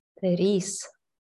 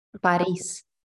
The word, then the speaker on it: París: Ok, entonces, la ciudad en inglés es Paris, pero en español, ¿cómo se pronuncia esa ciudad? – París. – So, París. – Ajá, porque Peris es así. E en español es sí. Pero París. Ok.